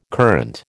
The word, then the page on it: current: American English